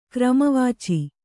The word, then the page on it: ಕ್ರಮವಾಚಿ: Kannada